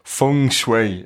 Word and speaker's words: Feng Shui: We've got Denise with us, and Denise is going to talk to us a little bit about, I'm going to try and pronounce this correctly here, Feng Shui is how you say it.